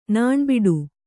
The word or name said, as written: ನಾಣ್ಬಟ್ಟೆ